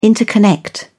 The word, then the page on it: interconnect: British English